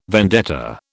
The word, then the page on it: vendetta: British English